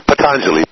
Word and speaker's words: Patanjali: Patanjali, P-A-T-A-N-J-A-L-I, Patanjali.